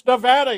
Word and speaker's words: Nevada: No, it's Nevada. You got to say Nevada. That's incorrect.